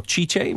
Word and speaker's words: Tite: Tite. – Or Chiche, would it be pronounced? – Yeah.